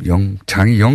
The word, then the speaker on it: 영장: 자, 바로 이어서 어, 김백준 전 청와대 총무기획관 그리고 김진모 전 비서관 구속 여부가 오늘 결정되는데 어, 영땅 아, 또 발음 방금 영장이 영땅으로 나가는 것도 너무하네요. 제가 해놓고도 영땅 전 전담 해설 법무부인 동아리 이정열 사무장 님되십니다 안녕하십니까? – 네, 안녕하십니까?